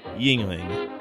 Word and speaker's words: Yuengling: Yuengling is pronounced, as we've been saying it, Yuengling.